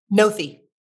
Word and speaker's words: Noethe: It's spelled N-O-E-T-H-E. – And it's Noth, not Nothe? – I think it's Noeth. – Noeth? Noeth. – Jolynn Nothe. I think that's how her name is pronounced. I've never actually heard her last name pronounced. – I do want to ask, because you've served as her spokesperson, how do you pronounce Jolynn's last name? – Nothe.